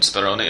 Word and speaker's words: Speroni: It's the actual real life Julian Speroni, or Julian Speroni, as he's just pronounced it himself.